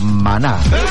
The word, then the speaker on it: Maná: Mis antiparras de repente me fallan, pero tiene acento en la A, que es el Maná.